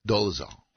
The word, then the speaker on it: Dolezal: One of them on the list, number 8, I can't say her name, Rachel Dolezal? – Dolezal.